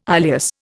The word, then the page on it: ileus: Indian